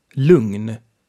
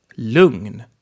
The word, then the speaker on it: lugn: G and N are pronounced a bit differently. They are pronounced ng. You know, you have the ng sound like in sing, and then the N after that. So, s-ng. Ng. So, ng. An example is the word for calm. Lugn. Say it. Lugn.